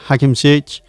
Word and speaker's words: Hakim Ziyech: Hakim Ziyech.